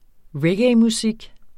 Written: reggaemusik

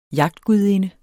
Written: jagtgudinde

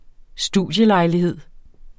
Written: studielejlighed

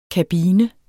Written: kabine

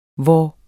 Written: vor

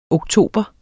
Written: oktober

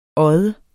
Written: odde

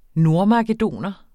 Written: nordmakedoner

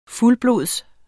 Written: fuldblods

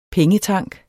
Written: pengetank

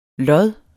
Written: lods

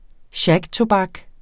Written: shagtobak

